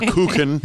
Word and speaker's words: Kuchen: Oh, had to go tell me what Coochin, what is it, Coochin? – Coogan. Yeah. – Why don't they spell it like that then? – Or Kuchen. – It's a German word for cake.